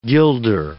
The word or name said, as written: guilder